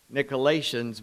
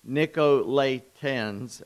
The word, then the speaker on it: Nicolaitans: I've been saying that wrong. Before I said Nicolaitans, but it's Nicolaitans, as the AI there, is before the T, not after.